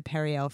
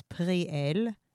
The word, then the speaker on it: Periel: It's transliterated to Periel from the Hebrew. In Hebrew, it's Periel, and it means fruit of God.